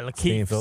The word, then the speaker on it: LaKeith: What you didn't sell me on was that the actor was it Lakeith? – Yeah, Lakeith Stanfield.